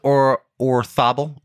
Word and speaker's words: Orzabal: I think it is Orthobel. Orthobel. His father was Spanish, and I'm pretty sure that's how you pronounce Z sound in Spanish names.